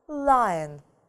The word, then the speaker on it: lion: Обращаем внимание, да? Читается не по правилам. Lion.